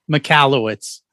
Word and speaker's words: Michalowicz: Michalowicz. – I love it. Say it again. – Michalowicz.